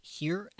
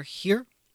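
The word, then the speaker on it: hir: H-I-R, I've heard it pronounced both here and higher. I generally hear here, and that's what I say.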